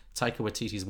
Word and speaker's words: Taika Waititi: Like, I think she fit, like Sam Rockwell, she fits into Taika Waititi's world.